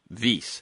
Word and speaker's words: Wyss: He's founding director of Harvard's Vyse Institute for Biologically Inspired Engineering, and that is a Swiss proper name, so it is spelled W-Y-S-S, Vyse.